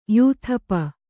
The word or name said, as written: ಯೂಥಪ